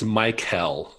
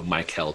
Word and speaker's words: Michael: It's Michael. So it's kind of an emphasis on the K. So Michael Chang.